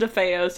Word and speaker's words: DeFeo: So, this evening, in 1974, 23-year-old Ronald DeFeo Jr. I realized that I think it's pronounced DeFeo. In doing all of this research, I've had in my brain DeFeo. but it's pronounced DeFeo, so if I accidentally say DeFeo, it's because I've just been hours of thinking it's DeFeo, so it's like when apologies.